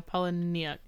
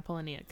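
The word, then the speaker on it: Palahniuk: She titled the post, "You're always haunted by the idea you're wasting your life," after a quote from novelist Chuck, I cannot pronounce this man's last name. – Oh. – Yes. And I promise I did not misspell. – Polyniuk. – Yeah, that. – No, Polyniuk. Yeah, Polyniuk.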